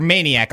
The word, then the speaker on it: Maniac: Who goes by the name of Mets underscore Manatic, or Maniac, I'm sorry.